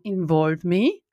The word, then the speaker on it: Involve.me: Nämlich, ich habe heute, wie schon gesagt, einen besonderen Gast, weil er ist einerseits so richtig ein cooler AI-Startup-Gründer, nämlich von InvolveMe, also Involve.me, muss man so aussprechen.